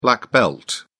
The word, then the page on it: black belt: British English